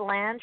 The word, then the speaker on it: Lange: Do you call it Lange or Lang? How do you pronounce the name? – Well, I call it Lang with an E.